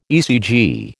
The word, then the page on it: ECG: American English